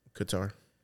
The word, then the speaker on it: Qatar: Is that how you say it? Qatar? Where the World Cup was? – Qatar.